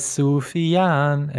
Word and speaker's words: Sufjan: Oh yeah, but he says Sufyan, and nobody says Sufyan.